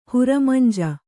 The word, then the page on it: ಹುರಮಂಜಿ: huramanji